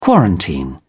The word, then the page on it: quarantine: British English